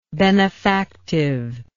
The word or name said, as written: benefactive